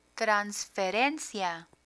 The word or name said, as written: transferencia